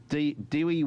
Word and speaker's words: Dewi: Dewey Weber. I don't even, that's not even how you say her name. D-E-W-I. Dewey Weber.